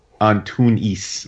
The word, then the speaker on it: Antunes: Well, I think normally I say João Antunes, but I think in Portuguese it's Antunes. Like, it doesn't... You don't roll the N.